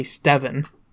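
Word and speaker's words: Stevan: And it can just all be pronounced Steven. But that's definitely Steven.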